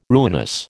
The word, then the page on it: ruinous: American English